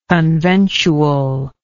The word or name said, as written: conventually